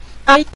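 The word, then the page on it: dai: tone 5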